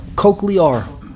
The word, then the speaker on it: cochlear: Cochlear.